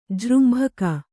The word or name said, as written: ಜೃಂಭಕ